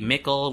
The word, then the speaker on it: Mikkel: Mikkle, Mikkel, which rhymes with Nickel.